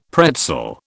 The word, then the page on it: pretzel: British English